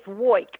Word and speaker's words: Woik: It's Woik, like I woik all day and woik all night.